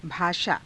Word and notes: bhaashaa: hindi

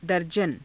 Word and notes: darjana: hindi

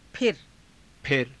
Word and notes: phira: Hindi

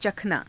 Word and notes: cakhanaa: hindi

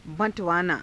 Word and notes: ba~_tavaanaa: hindi

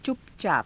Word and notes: cupacaapa: hindi

